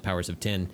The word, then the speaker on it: Powers of X: It's really supposed to be pronounced Powers of 10.